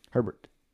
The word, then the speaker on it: Herbert: Brian Herbert. Herbert. I said that wrong.